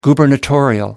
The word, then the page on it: gubernatorial: American English